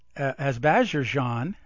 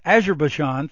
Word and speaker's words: Azerbaijan: It says there are signs that the current escalation between Armenia and Azerbaijan, and I butchered that, Azerbaijan, thank you, far from being incidental to the Nagorno-Karabakh conflict.